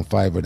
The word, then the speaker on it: Fiverr: How you pronounce Fiverr, right? – Fiverr.